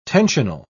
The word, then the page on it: tensional: British English